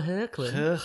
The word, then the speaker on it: Hoechlin: Oh, Tyler Hoechlin. – Which I'm assuming is how you pronounce it. – It is. You've got it 100% right.